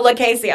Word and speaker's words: Lucchesi: am i saying that right it's like Casey. – Oh, La Casey. I was way off.